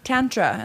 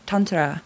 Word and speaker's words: Tantra: I always said Tantra, and then someone was like, it's... Actually, technically, it's Tantra with a kind of rolled R.